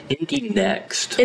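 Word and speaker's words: Indy NXT: And we saw like the Indy Lights, which is like Indy X now. – Indy Next. – Indy Next, yeah. – They spell it NXT.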